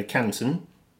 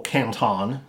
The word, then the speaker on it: Canton: in the Canton, which is flag talk for top left. – Mm-hmm. – Or Canton, as Americans say.